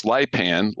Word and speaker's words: Lipan: It's Lipan, like frying pan.